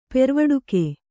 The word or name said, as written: ಪೆರ್ವಡುಕೆ